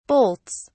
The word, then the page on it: bolts: British English